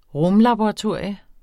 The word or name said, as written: rumlaboratorium